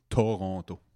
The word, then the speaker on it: Toronto: L'accent dans Toronto est dans le premier to, Toronto.